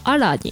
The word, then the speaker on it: ala: ただイタリア語ってスペイン語と似てるのでスペイン語でも、うん、 ALA って同じ綴りをするんですけど、うん、スペイン語で発音すると、うん、アラになるそうです。